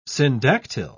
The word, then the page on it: syndactyl: British English